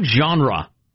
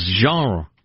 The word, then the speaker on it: genre: and it's not genre. It's genre.